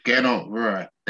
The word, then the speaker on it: Gernot Rohr: Gernot Rohr. Is that how you say it? – Yeah, Gernot Rohr. Yeah, that's it.